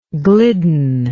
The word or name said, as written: glidden